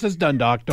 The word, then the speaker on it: Dundalk: So we're going to get to Mary and Dundalk. – Dundalk.